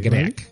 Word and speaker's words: Greak: Greak, Greak, eso es. – G-R-E-A-K.